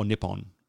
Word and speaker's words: Nippon: No, it's like Nihon or Nippon. Yeah, for some reason, it gets pronounced with a P sometimes, and sometimes it's an H.